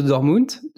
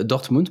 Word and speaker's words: Dortmund: et après, donc là, tu rentres de Dortmund, euh, Dortmund, pardon, c'est pas facile à, prononcer, hein. Dortmund,